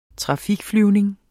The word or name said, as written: trafikflyvning